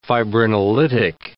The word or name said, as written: fibrinolytic